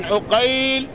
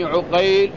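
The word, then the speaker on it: عُقيل: مثال ذلك: فمحمد ابن عقيل بفتح العين، ومحمد ابن عقيل بضمها، أسماء الرواة اتفقت محمد ومحمد، وأسماء الآباء اتفقت في الخط واختلفت في النطق. الأول عقيل بفتح العين، والثاني عقيل بضمها.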